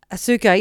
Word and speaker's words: Ahsoka: Ahsoka? – Ahsoka, yeah.